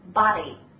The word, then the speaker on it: body: 首先我们来看一下第一个词 body, body. 请大家注意，首先这个发音呢，英音和美音也有一定的差异。那么我们说英音呢，大家比较习惯发的是那种短音的这个中等的这个 o，对了，我们的嘴型啊，中等的圆 o body。